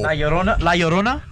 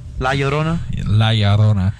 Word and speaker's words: La Llorona: Yeyorna. That's how you pronounce it, right, guys? – No. – La Llorona? – There you go.